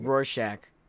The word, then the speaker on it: Rorschach: Yeah, Rorschach. Because it's German.